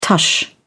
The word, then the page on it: tush: British English